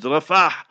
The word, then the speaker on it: Rafah: Is Rafah with a ha in the end. Learn to pronounce the words properly.